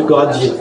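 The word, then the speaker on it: Goražde: Comme je ne sais pas le prononcer, comme lui il le met dans son livre, en fait ça s'appelle Goradjé.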